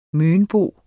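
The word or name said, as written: mønbo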